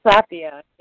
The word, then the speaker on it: Sapia: Sapia, yes, that's right.